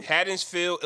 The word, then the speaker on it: Haddonfield: Haddonfield, Illinois, shut down the city of Halloween.